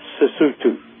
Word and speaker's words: Sesotho: Sesotho, is it Sesotho is the language? – Sesotho. – Sesotho is the language. – Yes. – And then all the O's are pronounced ooh.